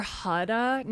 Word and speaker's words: Huda: But Huda Beauty, is that how you say it? H-U-D-A? Huda Beauty? – I think it's Huda or Huda. No, it's Huda. Yeah, I think you said it right.